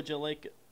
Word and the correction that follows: Jagielka: Oh, Jagielka? – He's 37 years old.